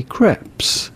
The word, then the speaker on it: crepes: The S at the end in French is silent, but in English or American, it'd be fine to say crepes.